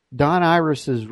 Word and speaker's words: Irace: And you spell his last name I-R-A-C-E, but you pronounce it Iris.